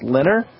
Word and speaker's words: LENR: Cold fusion, which has since been renamed Low Energy Nuclear Reactions, L-E-N-R, just pronounced Lenner.